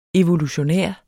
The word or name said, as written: evolutionær